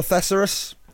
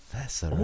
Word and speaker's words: Thesaurus: I'll do a couple of miles on the Thesaurus. I don't mind it. – Thesaurus. – Oh, you're hitting a different syllable there. It's a bit of fun. I would have pronounced it like a dinosaur.